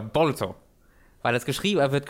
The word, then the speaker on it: Boruto: aber man spricht es am ehesten wie Bolto aus. – Ja. Bolto ja. was halt Bolt ist auf Englisch. Also das englische Bolt, japanisch ausgesprochen ist Bolto und wenn man das ausschreibt, steht da Boruto.